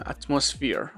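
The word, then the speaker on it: atmosphere: خب دوستان عزیز، اینم از داستان این قسمت که کلمات مهمش مثل سمتری، سمتری نیست، سمتری میشه قبرستان، گریو میشه قبر، اتمسفر میشه جو، اتمسفیر، البته تلفظ انگلیسیش اتمسفیره که میشه جو، جوه جای، تو جو زمین هم میشه.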